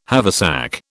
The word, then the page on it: haversack: British English